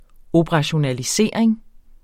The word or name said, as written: operationalisering